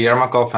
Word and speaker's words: Jermakov: I have to tell everybody he spells his last name Jermakov, and I had a brief and very pleasant pronunciation lesson from Sergey before the show.